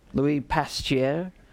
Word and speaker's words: Louis Pasteur: and I've been to France twice, so I know how to pronounce that, Louis Pasteur, announced the results of a groundbreaking experiment.